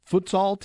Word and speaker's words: futsal: Otto, how did I say it anywhere close to correct? Good morning. Yes, you did very well with that. – Futsal. Okay, because I have no idea what it is until I started reading your bio, but that's, I don't want to skip ahead.